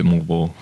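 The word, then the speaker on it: Moalboal: Okay, so the pronunciation I got off the internet is Moal Boal, but you're calling it Moal Boal.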